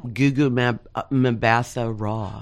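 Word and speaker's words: Gugu Mbatha-Raw: Gugu Mbatha Raw.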